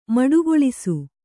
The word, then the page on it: ಮಡುಗೊಳಿಸು: maḍugoḷisu